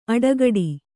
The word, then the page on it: ಅಡೆಗಟ್ಟು: aḍegaṭṭu